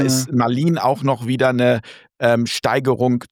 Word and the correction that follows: malignen: Sie sprechen von dem malignen Narzissmus. – Ich habe es falsch ausgesprochen, malignem, ja, malignem, ja, okay, malignem.